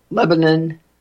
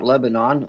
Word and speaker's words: Lebanon: Donald Trump just finished a great rally in Lebanon, Ohio, and that's how they pronounce it there, not Lebanon,